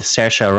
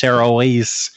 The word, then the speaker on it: Saoirse: If, for instance, you like the film Brooklyn, came out a couple of years ago, with Saoirse Ronan, which I finally realized how to pronounce her name, because I look at it and it's like, oh, it's Saoirse Ronan, and it's not a Saoirse Ronan.